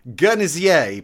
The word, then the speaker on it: Guernsey: Mefew means attention in the language of Guernsey, which is Guernsey, pronounced with a soft G, like the word gin. So Guernsey. It's three syllables. Guernsey.